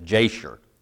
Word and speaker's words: Jasher: Is not this written in the book of, and the way this is supposed to be pronounced is Jasher. Jasher is what I would normally say, but I got this pronunciation Bible out, and it says Jasher.